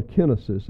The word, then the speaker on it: kenosis: or kenosis is the way it's pronounced in the Greek.